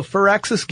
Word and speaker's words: Firaxis: Yeah, but it's pronounced Firaxis. Like fir tree.